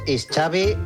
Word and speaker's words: Xavi: Like chocolate, My name is Xavi, like chocolate.